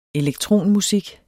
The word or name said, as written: elektronmusik